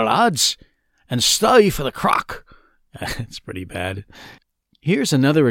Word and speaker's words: craic: It's not C-R-A-C-K, like you might think. Actually, it's spelt C-R-A-I-C. At first, I thought it would be pronounced crake, but a quick search on YouTube showed me I was wrong about that.